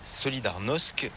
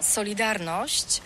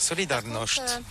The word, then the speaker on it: Solidarność: Alors solidarité, en français on dit Solidarnosc, mais ici à Gdansk on ne prononce pas Solidarnosc. – Solidarność. Par contre, Solidarność. – C'est assez difficile quand même. – Ça va encore. Solidarność.